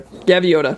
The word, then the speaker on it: Gaviota: and then probably the Hoka Gavoida. Am I saying that right? I have no idea. – Gavoida? – Is that a... – Gaviota. – Gaviota.